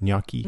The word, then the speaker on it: gnocchi: Spinach and basil pesto gnocchi. How do you say that? Gnocchi? – Gnocchi.